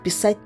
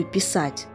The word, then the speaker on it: писать: Метр стихотворения требует для правильного звучания поставить ударение на второй слог в слове «писать». Однако существует фразеологизм со значением «пытаться противостоять непреодолимым трудностям», в котором такое же точно слово требует ударения на первый слог – «писать», а точнее даже его еще более вульгарного и просторечного синонима.